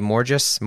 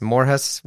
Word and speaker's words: Morjas: Um the pod this week, Morjas. I think I said it right. Uh, I did find out you can pronounce the name any way you want. You can say MORGES, Morhus, whatever. Each their own.